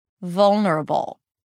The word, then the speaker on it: vulnerable: The emphasis is different because the first syllable is more stressed. So I engage more energy here. Vol, it's a little higher in pitch than the rest of the syllables that are soft, lower in pitch, and shorter. Vulnerable.